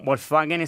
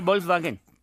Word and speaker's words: Volkswagen: Con F, sí, al principio. – ¿Cómo es? – En alemán es Volkswagen. Y lo normal sería Volkswagen. – ¿Y en español? – En español, Volkswagen.